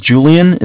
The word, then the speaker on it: Julian: Okay, my name's Julian. It's J-U-L-I-A-N. – Okay. – I'm calling with Bank of Cardiff. Cardiff is spelled C-A-R-D as in David, I-F-F as in Frank.